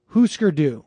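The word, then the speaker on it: Hüsker Dü: And it's pronounced Husker Du. What did I say? Husker Du? Husker Du.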